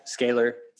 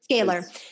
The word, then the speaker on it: Scalar: Scalar. – Scalar. Scalar energy. And from, you know, there's a lot of talk, like, especially in the circles of like, you know, spirituality or energy work about scalar energy.